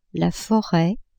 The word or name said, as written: foret